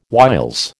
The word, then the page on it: whiles: American English